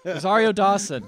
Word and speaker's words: Rosario Dawson: Rosario Dawson.